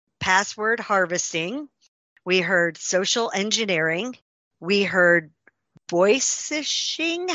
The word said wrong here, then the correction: vishing: How did you say that, Bryce? – Vishing.